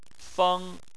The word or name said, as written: feng1